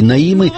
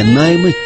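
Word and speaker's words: Naima: Я готов продемонстрировать вам еще несколько версий Наимы, не Наймы, как произносят некоторые, но сначала еще несколько слов о Мими Перан.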